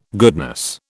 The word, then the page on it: goodness: British English